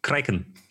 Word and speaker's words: Kraken: Korrekt ausgesprochen ist es Kraken.